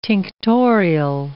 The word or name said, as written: tinctorial